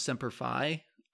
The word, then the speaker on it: Semper Fi: Uh, it's actually pronounced Semper Fi.